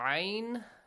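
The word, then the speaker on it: عين: Min al-kitabi yud'auna ayn.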